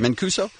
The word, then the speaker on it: Mancuso: Is I saying that right? – That's right. – Mancuso.